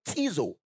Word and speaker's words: ktizo: The word created is the word tizo. K-T-I-Z-O. So the K is silent.